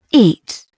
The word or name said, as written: eat